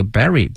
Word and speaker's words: bury: 比如说讲到了黛玉葬花，for example 或 like 黛玉 burying the petals 埋葬哈、啊，用这个 bury B-U-R-Y。同学们注意啊，这个词它发音。 bu 这个 u 呢要发成小的这个 i n bury，